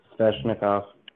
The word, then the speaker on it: Svechnikov: Svechnikov.